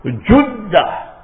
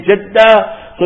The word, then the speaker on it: Jeddah: So both ways, if you go and speak to the real Arabs, the old sheikhs, some of them will say Juddah and some of them will say Jeddah.